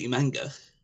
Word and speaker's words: Umaga: I thought you said Umaga. I'm like, oh, they picked Umaga. That's a pretty good pick. – Umaga is a good pick. No, because in our country, we pronounce it Umanga.